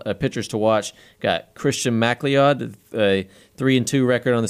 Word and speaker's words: MacLeod: M-A-C-L-E-O-D. – I think that's McLeod. – Okay, that's a weird way to pronounce it. – It is a weird way to spell it, yeah. But that's McLeod with an A.